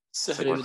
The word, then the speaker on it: Sioux: Sioux.